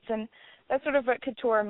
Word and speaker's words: couture: Couture? – Yeah, mm-hmm.